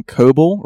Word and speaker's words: Coble: I made a YouTube not that long ago, and that is, if you search my name, Coble, rhymes with Noble with a C, Panetti, figure it out.